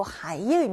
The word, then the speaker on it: 还: 이 하이는 이중 목적어 구문에서 환이라고 발음이 되기도 했었어요. 그래서 돌려주다, 반납하다의 의미가 있었죠. 他忘把那本书还给我. 그가 나에게 책을 돌려주는 것을 잊었다라는 뜻입니다. 이럴 때 환, 돌려주다라는 의미일 때는 환으로 읽어야 맞는다는 거.